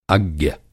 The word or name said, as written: अज्ञ